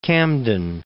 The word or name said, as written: Camden